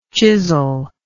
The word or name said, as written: chisellike